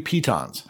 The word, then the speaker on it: piton: You also have these three pitons. I looked that up. That's how you pronounce it. It's a piton.